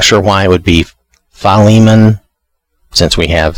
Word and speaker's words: Philemon: But it appears from what I'm seeing on different places and from different people that either Philemon or Philemon, you know, kind of a long, the Li is the one, is the syllable that's supposed to be, I think, the most stressed.